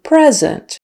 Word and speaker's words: present: P-R-E-S-E-N-T. The noun is present, with the syllable stress on the first syllable and with a short E. Present. Would you like to open your present? Or the other meaning of present is live in the present, not in the past. So, present. The verb, switch the stress to the second syllable, present, present, and more of an I sound, present.